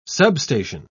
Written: substation

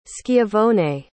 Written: schiavone

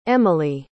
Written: emily

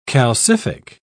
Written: calcific

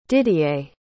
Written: didier

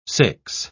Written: six

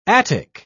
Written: attic

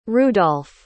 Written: rudolph

